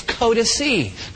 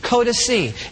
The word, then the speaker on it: codices: Spell that C-O-D-E-X. In plural form, it's codice. Codice. It's spelled this way if you're taking notes, C-O-D-I-C-E-S. That's a codice.